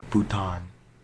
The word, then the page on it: Bhutan: English